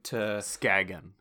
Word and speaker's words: Skagen: So to Skagen. It's pronounced Skagen.